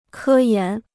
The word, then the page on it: 科研: kē yán